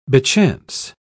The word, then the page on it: bechance: British English